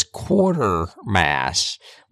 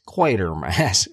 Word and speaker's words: Quatermass: Well, and I always thought it was Quatermass with an R, like a quarter coin. – Yep. – And not only is it not Quatermass, it's Quatermass, or at least that's how they pronounce it.